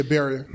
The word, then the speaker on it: Beria: Yeah, Beria.